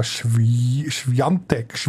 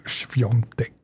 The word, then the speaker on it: Świątek: Gegen Diga Świątek spricht man so richtig aus. – Świątek schreibt man sie ja. Klär ich auch immer wieder, aber man sagt, ja, ich komme nicht raus bei diesen polnischen Betonungen. Wie schreibt es? Świątek und dann sagt man Świątek.